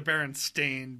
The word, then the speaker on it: Berenstain: Bravo, I'm pronouncing it correctly, by the way. The Berenstain Bears.